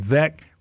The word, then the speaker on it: Veeck: he used to say Veeck rhymes with wreck, and he came up through this local DJ hated disco music.